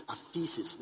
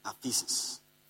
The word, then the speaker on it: aphesis: So in whom we have forgiveness, the word forgiveness is aphesis. Never forget. Aphesis. So when he forgave us, the word is what? It is spelled A-P-H-E-S-I-S. Aphesis.